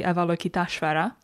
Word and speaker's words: Avalokiteshvara: The Chinese name Guan Yin comes down through the ages from the Sanskrit deity Avalokiteshvara. – That was an amazing pronunciation.